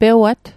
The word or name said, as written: pewat